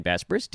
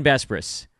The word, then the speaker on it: Besbris: I am your host, Dan Bespris, D-A-N-B-E-S-B-R-I-S. I think I just spelled my last name wrong. But who's counting? It's Dan Bespris.